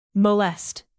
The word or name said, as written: molest